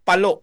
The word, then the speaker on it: Palo: Ano ba ang talagang tamang pagbigkas? Palo o palo? – Palo, palo, palo.